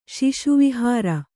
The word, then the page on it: ಶಿಶುವಿಹಾರ: śiśu vihāra